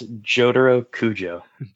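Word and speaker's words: Jotaro Kujo: It's Jotaro Kuju.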